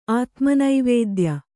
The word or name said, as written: ಆತುಮನಿವೇದ್ಯ